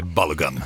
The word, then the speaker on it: Balogun: Anledningen, FIFA lät den amerikanska stjärnan Folarin Balogun, uttalar man det så? – Jag säger så, men jag tror att amerikanerna kan säga Balogun. – Okay. Balogun.